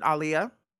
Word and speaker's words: Aaliyah: And I'm gonna pronounce it Aaliyah. Just to get in the habit,